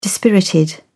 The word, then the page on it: dispirited: British English